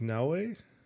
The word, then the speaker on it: Naoe: We have I believe her name is pronounced Naoe.